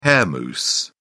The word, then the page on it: hair mousse: British English